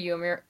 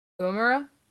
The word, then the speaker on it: Uemura: Buddy Matthews versus Yuya Yumir. I was doing so well. Uemura.